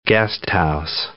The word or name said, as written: guesthouse